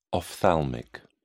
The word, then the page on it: ophthalmic: British English